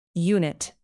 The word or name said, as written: unit